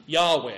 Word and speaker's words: Yahweh: That's the name which is used to translate this special name of God, YHWH, and it's a name which was most likely pronounced like this, Yahweh.